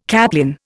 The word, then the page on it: caplin: Indian